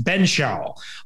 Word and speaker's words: Benzschawel: So I'm going to do the best we can and say it's Beau Benzschawel. Beau Benzschawel, yeah, is how I believe, and I remember his name being pronounced. Benzschawel, spelled Benzschawel.